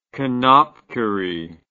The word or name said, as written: knopkierie